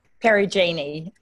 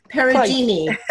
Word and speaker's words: Perugini: Michelle Perugini. Did I pronounce that correctly? – Perugini. – Perugini. Thank you.